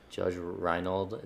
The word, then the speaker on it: Judge Reinhold: Judge Reinhold, is that how you say it? – Yeah.